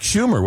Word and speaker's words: Schumer: I believe that was Chuck Schumer, was it not? – Yes. – Yeah. – Senator's name rhymes with rumor.